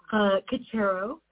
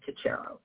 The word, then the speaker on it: Cocharo: Cocharo, I think you were doing a pretty good job. It's one of those Ellis Island butchered names from Italy that didn't start like Cocharo at all, but we've landed on Cocharo.